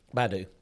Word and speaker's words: Baidu: Tesla also reportedly scored a deal with, I've never known how to pronounce that. Is that Baidu? – Baidu, yeah.